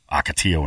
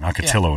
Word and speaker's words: Ocotillo: If all those four-wheel drive ones got found this year, which has blown me away, that they're gone before Ocotillo and Wrecker. Ocotillo, I'm sorry. I'm saying that wrong. Ocotillo and Wrecker.